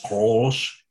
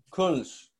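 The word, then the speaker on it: Kölsch: I know DiCarlo isn't that big of a fan of, how do you pronounce this anyways, Mr. Our German interpreter? – Krolsch. – It's K-O. – Oh. – And the umfla over it. L. – The umla. – The umla, yeah. That thing. And then S-C-H. Krolsch.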